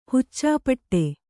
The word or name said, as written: ಹುಚ್ಚಾಪಟ್ಟೆ